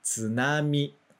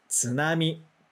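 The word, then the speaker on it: tsunami (津波): A gente tende a falar nan e ainda fala forte. Tsunami. Não existe nenhuma dessas pronúncias, nem a forte, nem a nasal, tá? A gente vai falar tsunami.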